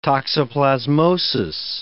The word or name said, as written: toxoplasmosis